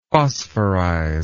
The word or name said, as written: phosphorize